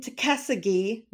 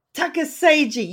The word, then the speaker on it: Tuckasegee: He lives in Tuckasegee, North Carolina. I hope I pronounced that right, David. No. What was it? Say it for me. – Tuckasegee.